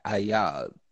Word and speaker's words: Ayaz: Ayaz.